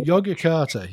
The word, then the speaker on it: Yogyakarta: But I did want to ask if you could tell us about the Yogyakarta principles. Am I pronouncing that oh, correct? Oh, yeah. Yogyakarta.